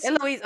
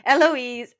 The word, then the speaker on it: Eloise: We see the women worried about Marina's pregnancy, and the girls like Eleanor, am I saying it wrong? – Eloise, oh my god, sorry, Eloise and Penelope wondering like, is it catching, like how did you, because they had no education.